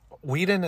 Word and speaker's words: Whedon: If that was the case, I think they should have delayed it because, and no bash to Josh Whedon. Whedon, is that how you say his name? – Yeah.